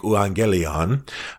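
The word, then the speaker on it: euangelion (εὐαγγέλιον): In the original Greek of the New Testament, good news is a word that is pronounced in Greek, euangelion. It's a kind of an EU sound at the beginning. Later generations hardened up that U and made it a V.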